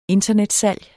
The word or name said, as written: internetsalg